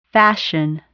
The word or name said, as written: fashion